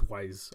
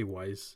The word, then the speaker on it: Weisz: It's Weisz, right? – It's Weisz. I say Weisz. I've heard Weisz.